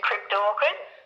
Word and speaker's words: cryptorchid: And also, pronounce this correctly for me, males with perhaps one retained testicle? – Yes, that's a cryptorchid. – Thank you. It's not a plant, it's a cryptorchid.